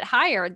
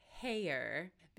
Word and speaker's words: Heyer: I've been pronouncing it Georgette Heyer because that is how Richard Armitage, Dickie Arms, the hot dwarf himself, Thorin Oakenshield, that's how he pronounces it at the beginning of his Audible.